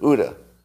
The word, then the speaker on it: OODA: I'm saying it wrong. Hoodoo loop? Loop? CUDA? OODA. The OODA loop.